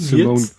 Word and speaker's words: Simone: Zum Beispiel, ich hoffe, sie wird Simone ausgesprochen. – Simone, Simone Giertz, ja, die gehört zu seinem Team ähm, mittlerweile, ja.